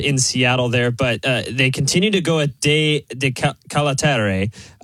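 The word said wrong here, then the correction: De Ketelaere: I think is exactly how you pronounce it. But Ketelare, sorry, excuse me.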